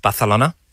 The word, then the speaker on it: Barcelona: Is it actually pronounced Barcelona? Are you supposed to say it with a bit of a lisp? – It is.